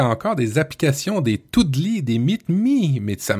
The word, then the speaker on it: Doodle: Qu'est-ce que c'est alors, euh, alors, moi je le prononce Doodle, mais je me, trompe peut-être. Hein. c'est Doodle et Meet euh, donc D-O-O-D-L-E et, ben Meet Me.